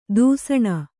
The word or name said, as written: ದೂಸಣೆ